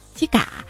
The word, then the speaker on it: 肩胛: 再说到这个上海话，这位叫两块钱的朋友说，我表弟跟他妈说今天一女孩亲了我肩膀一下嘛，但是上海话的肩膀发音呢是肩胛，就是鸡嘎，不是鸡膀，然后他妈就大怒，谁亲了你鸡巴一下。